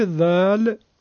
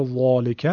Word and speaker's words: ذال: برای اینکه اگر بشه ذالکه با یک حرف دیگه‌ای که خواهیم گفت اشتباه خواهد شد. ذالکه. خب پس به این ترتیب حرف ذال را نوک زبانی با صدای نازک و کم حجم تلفظ میکنیم که به این کم حجمی و صدای نازک حرف ها میگن ترقیق.